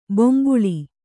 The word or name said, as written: ಬೊಂಬುಳಿ